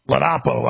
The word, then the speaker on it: Ladapo: Ladapo.